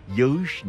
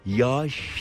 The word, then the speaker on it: yağış: Oysa biliyoruz ki yağış biçiminde değil de yumuşak G'yi söylemek yerine kendisinden önceki A sesini bir ölçü uzatarak yağış şeklinde söylüyoruz.